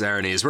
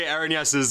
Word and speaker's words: Erinyes: It's Erinyes. We're going to go back and forth. Apparently, Erinyes is an alternative pronunciation that is still valid.